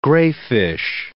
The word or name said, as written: grayfish